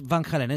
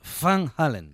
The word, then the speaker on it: Van Halen: Que además no es Van Halen, es Van Halen.